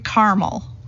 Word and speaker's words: caramel: caramel. Said with two syllables here, caramel.